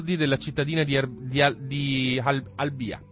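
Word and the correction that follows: Halabja: Non è Al- Halabia. Halabia. Halabia.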